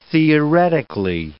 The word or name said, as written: theoretically